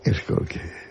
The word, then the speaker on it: Skolsky: ¿Cómo se pronunciaría en polaco Skolski? – Skolski. Como tú lo has dicho.